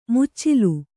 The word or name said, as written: ಮುಚ್ಚಲ